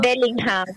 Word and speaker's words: Bellingham: El Willington, yo le digo al Willington, versión morocho aquí. Usted lo sabe pronunciar mejor, amiga. ¿Cuál es el chocolatito medio cruzado? – Bellingham.